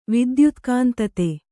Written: ವಿದ್ಯುತ್ಕಾಂತತೆ

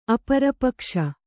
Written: ಅಪರಪಕ್ಷ

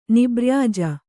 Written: ನಿಬ್ರ್ಯಾಜ

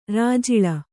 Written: ರಾಜುಳ್ಳಿ

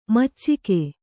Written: ಮೋಚಿಕೆ

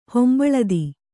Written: ಹೊಂಬಳದಿ

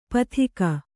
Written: ಪತ್ತಿಗ

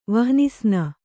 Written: ವಹ್ನಿಸ್ನ

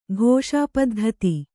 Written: ಘೋಷಾಪದ್ಧತಿ

